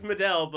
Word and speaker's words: Medel: I'm like, "Oh, it's Medel," but I just say metal, or no, I just say like whatever to fuck with them.